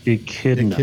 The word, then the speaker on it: echidna: It's pronounced echidna. – Echidna. – What did you say? – I fucked it up.